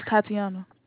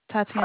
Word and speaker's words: Tatiana: My name is Tatiana. – Who am I talking to? – Tatiana. – How you spell it? Just spell it for me. – T-A-T-I-A-N-A. – T-A-T-I-A-N-A? – Yes, ma'am.